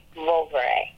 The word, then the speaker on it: Vouvray: It's spelled V-O-U. – Okay. – V-R-A-Y. Vouvray.